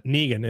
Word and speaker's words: Negan: Yeah, it's Negan. Yeah. – Negan in AMC's Walking Dead.